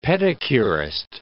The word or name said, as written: pedicurist